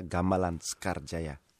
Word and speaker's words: Gamelan Sekar Jaya: And Dewa Barada performs this with a local Balinese group, Gamelan Sekar Jaya. Have I pronounced that okay? – Yeah, Gamelan Sekar Jaya.